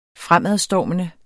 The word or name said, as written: fremadstormende